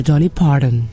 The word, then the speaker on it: Dolly Parton: ¿cómo se dice Dolly Parton? – No, señor, Dolly Parton, y usted sabe.